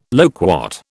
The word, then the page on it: loquat: British English